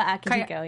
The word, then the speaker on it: Akihiko: Akihiba. Or did I say his name? – Kayaba Akihiko.